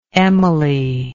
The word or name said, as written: emily